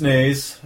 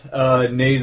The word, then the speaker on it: Naes: YouTube channel is my name, Chris Nays. Nays is spelled N-A-E-S.